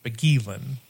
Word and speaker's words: Beguelin: B-E-G-U-E-L-I-N. Bogielin. That's how I would pronounce that.